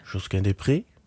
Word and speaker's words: Josquin Desprez: Ik weet niet precies, hoe spreek je dit uit? – Josquin Desprez.